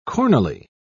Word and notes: cornily: British English

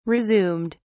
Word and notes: resumed: British English

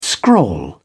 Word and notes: scrawl: British English

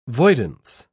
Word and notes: voidance: British English

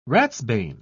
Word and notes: ratsbane: British English

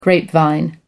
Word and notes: grapevine: American English

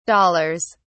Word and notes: dollars: British English

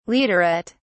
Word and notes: leaderette: British English

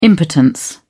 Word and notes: impotence: British English

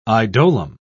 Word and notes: idolum: British English

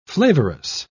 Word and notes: flavorous: British English

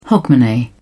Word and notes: hogmanay: British English

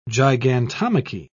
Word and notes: gigantomachy: British English

